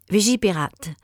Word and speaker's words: Vigipirate: And on October 13th, the Prime Minister of France raised the security threat level with the, now, how do you say the Vigipirate? – Oui, Vigipirate.